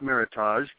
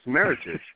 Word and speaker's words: Meritage: So you just heard me there pronounce it wrong. It's not Meritage. There's no need to Frenchify everything. It's Meritage.